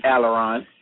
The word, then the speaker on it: Aleron: Our special guest today is the author of the book, and I hope I'm pronouncing this correctly, Aleron. Aleron, correct me if I'm... – it's Aleron. Good try.